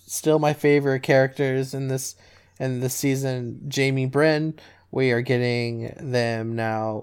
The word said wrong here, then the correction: Brienne: Can you say her name again for me? – Brienne. – Just curious. Oh, okay. – Yeah, did I not say Brienne? – I thought you said Brynn.